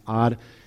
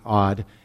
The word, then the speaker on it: Ad: The first one is Ad, which is not odd. It's actually just pronounced that way.